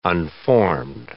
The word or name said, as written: unformed